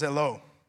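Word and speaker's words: zelo (ζηλόω): It's pronounced zelo. It's the same word that we get zeal or zealous or jealousy from.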